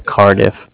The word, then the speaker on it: Cardiff: It's C-A-R, like car, and then D-I-F-F, diff, like different, Cardiff.